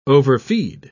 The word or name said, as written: overfeed